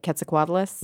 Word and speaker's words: Quetzalcoatlus: Maybe they've heard of, tell me if I'm pronouncing it right, Quetzalcoatlus? – Mm-hmm.